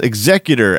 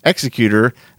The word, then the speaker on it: Executor: He says, to help answer your confusion, Vader's destroyer is pronounced executor as in executive, a maker of policy, not the other way, executor as in killing someone. That word is executioner.